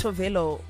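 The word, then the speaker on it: Tove Lo: It's Tavlo. Oh, apparently it's actually pronounced Tovelo or something like that. Tavlo.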